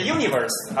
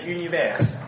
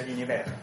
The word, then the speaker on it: Univers: 有些人念的 universe 啊，我随便念了，我不管它了。但是正式的应该念 universe 啊，发音，嗯，发音是 s x 发音的，所以叫 universe 啊。它基本上是跟 Helvetica 是一类的，而且呢，我个人更喜欢用 universe universe。嗯，因为呃，说实话，Helvetica 它排正文并不好，很挤。用 universe 很明显，大家去看 universe A B C A 到 Z 长度。